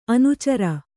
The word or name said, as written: ಅನಾಚಾರಿ